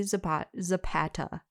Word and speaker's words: Zapata: So, we're going to talk about Angie Zapata. I hope I'm saying her name right. – Yeah.